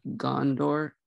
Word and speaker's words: Gondor: The four options: the Shire, Rohan, Gondor. I don't know how to say that. How do you say that word? – Gondor. Yeah. – Oh, Gondor. Okay.